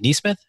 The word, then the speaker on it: Nesmith: And then number 11, Aaron Nesmith. Is it Nesmith? Am I saying that right? – Nesmith.